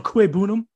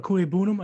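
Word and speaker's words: Okwuegbunam: I'm going to butcher his last name. I'm so sorry. Ogunbowale? – Akuebunam? – Albert Akuebunam, I think is how you say it.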